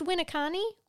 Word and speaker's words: Winneconne: So let's talk about, is it pronounced Winneconne? Winneconne, Wisconsin? – Spell it. – Oh, W-I-N-N-E-C-O-N-N-E.